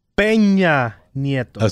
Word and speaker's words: Peña Nieto: Rick Peninita. – Peña Nieto. – So how do you say former president, former Mexican president? – Enrique Peña Nieto.